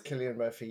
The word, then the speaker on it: Cillian: Well, the thing I want to say is it is Cillian Murphy.